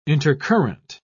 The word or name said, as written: intercurrent